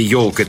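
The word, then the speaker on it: ёлка: По легенде, директору Академии наук и художеств княгине Дашковой не понравилось, что в слове «елка» пишется «е», а произносится «йо».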